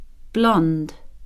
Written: blond

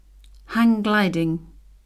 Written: hang-gliding

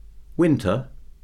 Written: winter

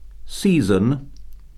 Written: season

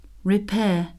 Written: repair

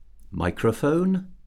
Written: microphone

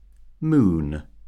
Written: moon

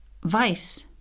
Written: vice

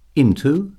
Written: into

